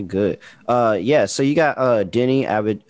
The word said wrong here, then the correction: Avdija: Uh, how you say his name? – Deni Avdija. – Uh, Avdija. – Yes, yeah, Deni Avdija for the Wizards.